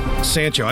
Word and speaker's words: Sancho: Sancho. I said Sancho like you buttholes. – You said it right. That's how you say it.